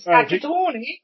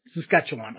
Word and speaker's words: Saskatchewan: It's Saskatchewan, is it Saskatchewan? Is that how you pronounce it? Saskatchewan. Okay. Saskatchewan.